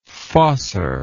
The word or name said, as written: fossor